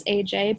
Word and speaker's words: Ayjay: But for those of you who don't know, my name is AJ, but I spell it AYJAY.